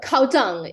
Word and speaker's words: Cao Zheng: So Cao Zheng is how we would pronounce that. Yeah, so the ZH is like a J sound.